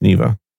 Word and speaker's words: Neeva: Neeva. Oh, the N E E V A. – Is it? – Or? Yeah, yeah, it's pronounced Neva.